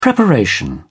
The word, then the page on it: preparation: British English